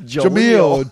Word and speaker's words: Jaleel: I'm pretty sure you said Jamil, and then I said it too. I'm like, wait a minute. Do we both just call him Jamil? It's Jaleel. Jaleel.